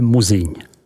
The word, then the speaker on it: Musigny: Musigny, vyslovujeme Muziň.